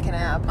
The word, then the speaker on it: Kanab: The locals pronounce it Kanab.